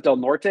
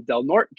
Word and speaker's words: Del Norte: Give us a little background about, I said it wrong. I thought it was Del Norte. I butchered that one. Del Norte County there.